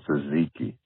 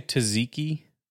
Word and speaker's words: tzatziki: And tzatziki. The T is silent. Delicious. – Did you say tzatziki?